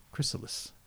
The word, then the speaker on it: Chrysalis: Tell me Chrysalis. I said it right. – Yes.